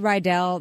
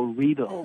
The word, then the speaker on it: Riedel: Because I said Riedel like an American. How is the proper pronunciation? – Well, it rhymes with needle, so Riedel. – Riedel. – Right. – Okay. Riedel. – Riedel. Riedel. Like Riesling.